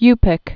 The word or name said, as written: Yupik